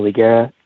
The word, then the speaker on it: Higuera: It's P-R-O-F, so prof.dhiggs, D-H-I-G-S. My last name is Higuera, so I just kind of cut it down to D Higgs. So again, Instagram is at prof.dhiggs, and then my LinkedIn is just my full name, Daniel Higuera. Higuera is spelled H-I-G-U-E-R-A, and I'm a professor at Santa Ana College, so my email would be Higuera, so my last name underscore Daniel at sac.edu.